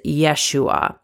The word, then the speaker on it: Yeshua: As a way to honor the original names of God this week, I will be using the closest pronunciation of the name of Jesus that I can find, as he would have been called in his own language, which is Yeshua.